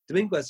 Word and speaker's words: Dominguez: And then, how do you say his name? Dominguez? No, how does McLaren say his name? – Dominguez. – Dominguez just puts on a masterclass.